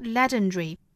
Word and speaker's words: legendary: Whatever you do in this life, it's not legendary，这个 not 尾部的 t 省读，注意英式发法呢是 not It's not legendary. 传奇的这个单词呢，英式和美式的发法是略有区别的。英式会读成 legendary，会把这个 schwa sound 给省掉，直接的和 r 连接，读成一个 dr，再一加后面的元音呢，就变成了 dre，legendary。那美式发法呢，我们看音标其实是一个 dairy，legendary 啊。